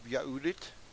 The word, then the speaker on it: Yahudit: So let's get started in the first chapter of this book of Yahudit. I'm going to pronounce it that way. Because that is the proper Hebrew pronunciation of this, and the Greek, this is written about Hebrew people, okay?